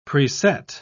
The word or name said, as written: preset